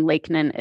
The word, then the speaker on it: Lakenan: And then my last name, Lakenan, is spelled LAKEN as in Nancy, AN.